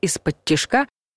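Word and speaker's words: исподтишка: Изначально «из-под тишка» с ударением на последнем слоге использовалось со смыслом «тихонько», «спокойно», а в современном языке употребляется несколько в ином значении — «скрытно», «тайно», «украдкой».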